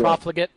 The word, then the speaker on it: profligate: I wouldn't say he's been particularly profligate. Is that how I pronounce that word? – Anyway, profligate, yeah. – Profligate, indeed.